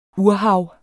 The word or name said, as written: urhav